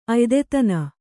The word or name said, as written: ಅಯ್ದೆತನ